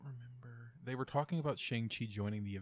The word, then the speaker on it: Shang-Chi: Because that's the right way to say it. Shang-Chi. – Yeah. – Shang-Chi.